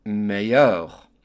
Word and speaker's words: meilleur: The word meilleur in French is spelled M-E-I-L-L-E-U-R. The L's are silent, and the M-E-I sounds like A, and the E-U-R sounds like yeur. So when we put it all together, we get meilleur.